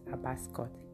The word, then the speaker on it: Abba's Court: Now, if you don't understand the way I pronounce, let me just spell out Abba's Court. ABBA, then apostrophe S, then COURT.